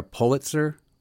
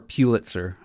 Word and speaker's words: Pulitzer: Are you a Berkeley academic looking for a Pulitzer or Pulitzer? How do you say it? – Yeah, either way. I don't know. Pulitzers. I've heard it pronounced either way.